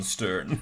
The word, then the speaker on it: Sturn: And it's spelled S-T-U-R-N, Stern.